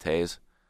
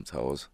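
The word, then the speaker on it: Toews: But growing up, when I first went to a Blackhawks game, I loved Taze. Never could pronounce his name right. Called him Toes.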